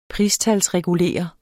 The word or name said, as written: PRISTALSREGULERE